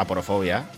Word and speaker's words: aporofobia: ¿Lo he dicho bien? ¿Pobrófobo? – No. – Sí, ¿no? ¿Pobrófobo? – ¿Qué? ¿Cuál es? – ¿No? Aporofobia.